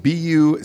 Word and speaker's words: Buc-ee's: It is spelled B-U-C-E-E-S or Buc-ee's.